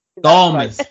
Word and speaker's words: Garmus: Garmus.